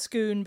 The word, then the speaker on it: scone: To clarify, it is pronounced scoon, but it is spelled S-C-O-N-E.